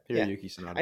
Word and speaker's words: Hiroyuki Sanada: Hiroyuki Sanada. – Yeah, Hiroyuki Sanada.